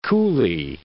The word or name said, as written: Grand Coulee